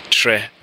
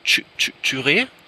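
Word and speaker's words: Kyereh: Aber für alle KY wird im Ghanaischen Tsch ausgesprochen und deswegen ist ganz korrekt Tre.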